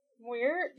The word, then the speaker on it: Muir: By, I don't know how to pronounce her name. Tamsin Muir? – Muir? Yeah. – Sounds right.